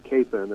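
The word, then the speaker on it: Kapaun: But they apparently pronounced it Capon. – Mm-hmm. – But the fellow POWs in Korea that were with him all pronounced it Capon. So you hear both sides even here in Wichita, but the more common pronunciation is Capon, and we have a Catholic high school named after him, so it's Capon Mount Carmel High School. So I go with Father Capon.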